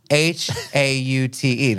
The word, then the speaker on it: haute: H A U T E. That's haute, like haute couture.